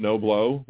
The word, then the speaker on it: Knobloch: Chuck with that last name, Knobloch, was Chuck Knobloch. And it was really pronounced No Blow.